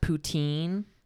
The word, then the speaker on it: poutine: Fuck that, it's technically pronounced Quebec, and it's also technically pronounced poutine.